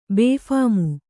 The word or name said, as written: ಬೇಫಾಮು